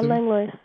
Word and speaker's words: Langlois: Am I saying that correctly? – Yeah, Langlois. – Yeah, Langlois. – It's actually Langlois if you speak French, but no one here does.